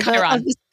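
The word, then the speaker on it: chyron: Chiron, the thing at the bottom of Chiron. – Chiron, and all the ads, the betting ads were bananas.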